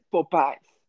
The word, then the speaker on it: Popeyes: Exacto, en inglés no se dice Popeye, en inglés se dice Popeyes.